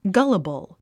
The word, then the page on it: gullible: ɡʌ́ləbl; ガァラボォ